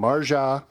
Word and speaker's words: Marja: Marja, Helmand Province, Afghanistan, in 2010.